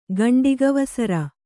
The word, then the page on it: ಗಂಡಿಗವಸರ: Kannada